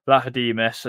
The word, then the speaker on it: Vlachodimos: So we've got Matt Turner, Odysseus Vla- Vlachodimos, as uh, someone pointed out that that's how you say it.